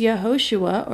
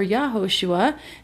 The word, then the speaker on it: Yehoshua: The pronunciation of Joshua's name in Hebrew is Yehoshua or Yahoshua.